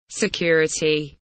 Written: security

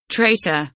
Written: traitor

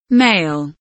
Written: male

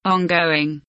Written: ongoing

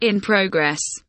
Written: in progress

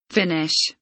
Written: finish

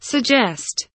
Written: suggest

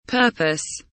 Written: purpose

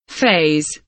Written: phase

